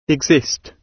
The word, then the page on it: exist: British English